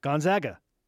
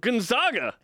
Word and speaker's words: Gonzaga: Gonzaga's the other one. You and I are like, I feel like we're the only two media members that consistently say it correctly. – Gonzaga.